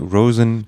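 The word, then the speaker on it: Róisín: Nee, wird R, dann ein O mit Apostroph, I-S, I mit Apostroph, I-N, aus Irisch, Roisin, geschrieben. – Oh, zu viele Apostrophe. Hat aber, glaube ich, ja. Roseanne ausgesprochen, Roseanne Murphy, in Kollaboration mit DJ Kotze mal wieder.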